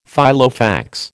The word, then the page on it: Filofax: American English